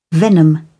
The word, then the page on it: venom: British English